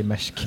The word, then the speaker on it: Maersk: Eh, och det här är eh, det danska rederiet Maersk, eller hur uttalar man det? Maersk. Mersk. Mm. Jag säger Mersk. – Ja, Mersk.